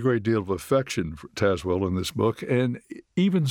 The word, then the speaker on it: Tazewell: Well, let's begin with your favorite place on the planet, Tazewell, Virginia. Take us there, if you would. – Well, first of all, I've got to commend you on pronouncing it right, because I rarely hear people pronounce it correctly.